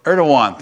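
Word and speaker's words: Erdoğan: Erdogan, thank you.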